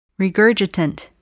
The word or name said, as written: regurgitant